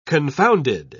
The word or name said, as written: confounded